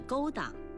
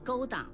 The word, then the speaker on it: 勾当: 有不少人读勾当，说秘密勾当、不可告人的勾当、罪恶勾当等等。请朋友们注意，勾当的勾应该读去声，不能读成音频。